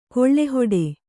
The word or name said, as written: ಗಾಳಿಹಿಡಿ